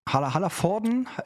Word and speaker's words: Hallervorden: Und äh, Dieter Hallervorn. Genau. Hallervorden, Dieter Hallervorden. Ich, ich nuschel das einfach immer weg, damit man nicht weiß, ob es richtig ausgesprochen ist.